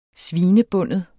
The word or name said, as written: svinebinde